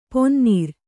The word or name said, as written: ಪನಿನೀರು